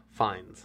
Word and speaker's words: Fiennes: And I hate this dude's name, because it's spelled Ralph, but it's Ralph Fiennes.